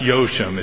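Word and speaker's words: Jauchem: it's pronounced Yochem, it's J-A-U-C-H-E-M, for the United States Air Force, which was evaluating this technology for use by the Department of Defense.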